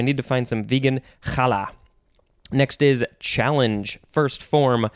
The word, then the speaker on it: challah: This is a Yiddish word. They spell it K-H-A-L-E from the Hebrew H-A-L-L-A-H. Challah.